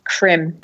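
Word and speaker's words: Creme: Yeah, just to verify this, I know it's kind of a tangent, but yeah, Benjamin Krem, I think it's pronounced Krem. – It's Krem. – Krem. – You know, with a little accent.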